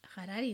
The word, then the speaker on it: Harari: en que decía Noah Yuval Harari, ¿no? ¿Lo he dicho bien? – Sí.